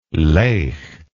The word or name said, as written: laigh